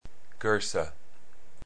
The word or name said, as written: Goesser